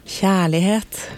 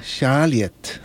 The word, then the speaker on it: Kjærlighet: Kjærlighet. – Kjærlighet.